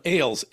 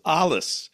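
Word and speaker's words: Ales: He was a spy who had the code name, I call him Ailes, A-L-E-S. I've also heard it pronounced Alice.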